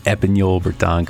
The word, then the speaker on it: Epagneul Breton: Also brought to you by Trinity Bretons, home of the Epignol Breton. I've been told that I actually say that correctly, so I'm very proud of saying the Epignol Breton correctly.